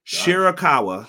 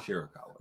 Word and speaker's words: Shirakawa: We had Mina Shikara. Excuse me. Did I say that right? Shirakawa. – Shirakawa.